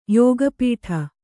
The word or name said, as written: ಯೋಗಪಟ್ಟ